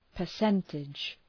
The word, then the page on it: percentage: pər’sentıdʒ